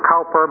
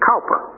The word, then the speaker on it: Cowper: Now, I know some people pronounce it Cooper, some pronounce it Cowper, but his name is William Cowper.